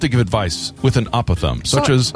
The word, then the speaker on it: apothegm: P-O-T-H-E-G-M. – Oh, come on. – The old silent G routine. – Like a phlegm kind of word? – Exactly. – Okay. – Apothegm.